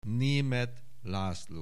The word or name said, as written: Németh László